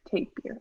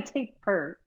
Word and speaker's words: tapir: I'll say that now. I was calling it a taper. Tape. Anyways, I won't get into that. – I think pronunciation. Tape. Yeah, I've heard taper. That's a, yeah. It's acceptable. – Mm-hmm. We accept all pronunciations of the word.